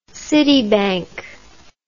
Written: citibank

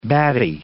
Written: batty

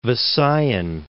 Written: Visayan Islands

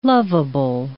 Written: lovable

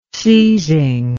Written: seising

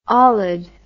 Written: olid

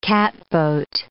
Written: catboat

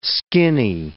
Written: skinny

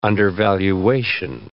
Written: undervaluation

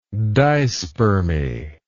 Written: dispermy

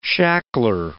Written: shackler

